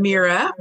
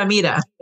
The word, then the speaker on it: Mira: I'm Mira Weinstein. – Oh, I'm sorry. Sorry for saying it wrong. – If you speak Spanish, it's like Mira, yeah. Mira.